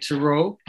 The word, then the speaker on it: Tureaud: That's spelled the French way, T-U-R-E-A-U-D. And I'm pronouncing it Turaud.